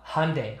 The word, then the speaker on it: Hyundai: So you, mesmo que escreva Hyundai, the correct way to say it in American English would be Hyundai.